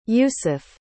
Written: Youssef

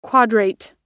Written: quadrate

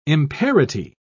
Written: imparity

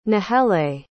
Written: nahele

